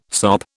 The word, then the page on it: sop: British English